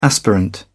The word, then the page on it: aspirant: British English